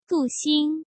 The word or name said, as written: Du Xing